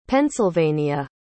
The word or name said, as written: pennsylvania